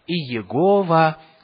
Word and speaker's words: Иегова: И некоторые это озвучивают как Иегова, кто-то говорит Яхве, Джехова, по-английски и так далее. И причина тому, что мы не знаем, как точно произносить это имя, заключается в том, что его столько долго не произносили, что люди забыли, какие там нужно гласные вставлять.